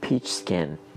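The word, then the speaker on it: peach skin: واژه درست انگلیسی این نوع پارچه که صحبتش رو دارم الان خدمتون میکنم به این شکل است پیچ سکن پیچ سکن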